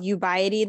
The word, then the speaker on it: Ubiety: And it was called Ubiety. That's how you pronounce that? – Yes. Yeah.